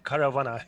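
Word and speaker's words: Karavana: The one in Istanbul how would I pronounce it? Caravana. It's like a caravan with a K. Almost.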